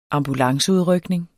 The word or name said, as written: ambulanceudrykning